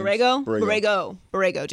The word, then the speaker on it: Borrego: James Borrego, that's how you say his name? – You know how I say names. – Borrego. James yes.